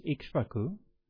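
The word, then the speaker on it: Ikṣvāku: and the founder of the solar lineage was King Okaka, is the Pali pronunciation. The Sanskrit pronunciation is Ikshvaku, and Ikshvaku is derived from the word for sugar, or sugar cane.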